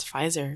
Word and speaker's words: Pfizer: And the fourth P of the week is a silent P. It's Pfizer, because we're fully vaccinated bitches now.